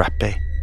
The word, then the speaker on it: Rappe: Nå la hun til en ekstra E i etternavnet, og endret samtidig uttalen til Rappay. Hun følte selv at Virginia Rappay hørtes ut som en langt mer verdensvant kvinne enn Virginia Rapp.